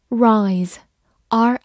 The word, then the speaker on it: rise: rise，r i s e，rise，字母 i 发它本身的音 i，s 发 z，末尾的 e 不发音。